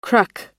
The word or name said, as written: cruck